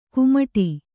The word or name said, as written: ಕುಮಟಿ